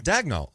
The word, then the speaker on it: Daigneault: Apparently it's Deg. Like, they say it Daigneault? – Deg. Like, it's D-E-G. – Daigneault. – Daigneault. – Nolt? – Nolt. – It's Daigneault.